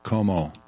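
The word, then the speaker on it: KOMO: Como, yes, it rhymes with the FBI, Como, right? No, the governor of New York, Como.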